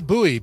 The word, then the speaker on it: Buoy: Yeah, that was Dana Bowie, B-U-O-Y, Dana Bowie. Don't spell it like David Bowie, because that's not at all what the name is.